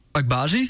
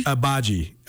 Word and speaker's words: Agbaji: Agbaji? – Agbaji. – Ochai Agbaji.